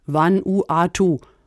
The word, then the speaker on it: Vanuatu: Vanuatu. Habe ich das richtig ausgesprochen? Ja, ganz genau.